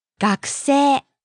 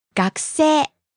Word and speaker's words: 学生: The next word is 学生. – Student. – The second part has two syllables, which are せい. But it's せ. 学生. – It's a long vowel.